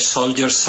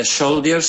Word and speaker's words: soldier: Now take the first syllable. How do you say the first syllable? – Ger. – So soldier. Even though it's spelled S-O-L-D-I-E-R. Soldier. Soldier.